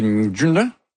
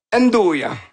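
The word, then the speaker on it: 'nduja: Le truc dans le milieu, ça, c'est un truc qui se prononce très mal. C'est le Ndjuna? – Ndoya.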